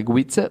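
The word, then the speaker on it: Guice: Ist, äh, spricht man das Gies aus? Du meinst ja. Gu, Gu, Guize? Ja, Guize, weil, also Peterson war stark, also generell das Running Game Peterson war der hat gut, Das Running Play war halt gut.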